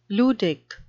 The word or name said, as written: ludic